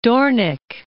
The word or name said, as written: Doornik